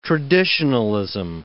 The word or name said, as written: traditionalism